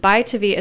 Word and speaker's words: ByTavi: Buy to V is spelled B-Y-T-A-V-I.